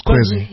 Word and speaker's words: Kwezi: So how do you come up with is it Kwezi? Am I saying it right? – That's right. That's Kwezi.